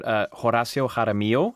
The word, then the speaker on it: Horacio Jaramillo: Horacio Jaramillo is how I would pronounce that. – Wow. – With my sort of Central American Spanish.